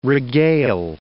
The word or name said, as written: regale